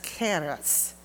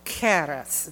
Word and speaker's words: charis: The first one looks like charis, but it's pronounced charis. Charis. It's a very hard K.